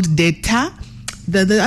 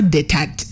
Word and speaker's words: Deta: The matatu is called Deta. The other T is silent, just FYI. It's not Detat. It is Deta, like coup d'etat.